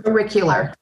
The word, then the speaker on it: auricular: Ar- auricular.